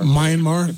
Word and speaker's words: Myanmar: Myanmar. – Myanmar.